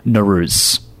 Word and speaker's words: Nowruz: But just so everyone knows, I'm going to be collectively calling it Nowruz.